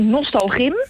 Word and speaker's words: Nostalgym: Hoe gym er vroeger uitzag, zeg ik dat goed? – Ja, nou, Nostalgym. – Nostalgym, dat is een activiteit.